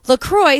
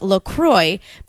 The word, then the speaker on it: LaCroix: Officially, it's pronounced LaCroix, but it rhymes with enjoy, not La Croix or La Croix.